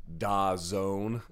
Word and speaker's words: DAZN: Plus, I want to talk a little bit about Da Zone. I've been pronouncing it like it's been written for all this time. And had someone write me being like, you know, it's pronounced DAZN, right?